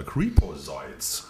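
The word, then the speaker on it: Creepozoids: Ich weiß gar nicht, wie es ausgesprochen, wie es aussprechen soll. Creeposoids heißt es.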